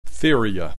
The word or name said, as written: Theria